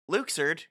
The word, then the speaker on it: Luxord: Luxord, whose name is apparently pronounced that way, and Vexen...